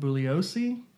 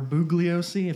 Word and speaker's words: Bugliosi: His name is Vincent Bugliosi, or Bugliosi, if you want to pronounce the G.